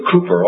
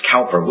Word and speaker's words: Cowper: William Cooper, often mispronounced Cowper, William Cooper said about his conversion, the full beams of the Son of Righteousness shone upon me.